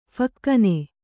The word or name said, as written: ಫಕ್ಕನೆ